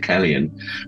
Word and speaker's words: Cillian: I'm sure he pronounced it Kellyan, which is where I took the name from.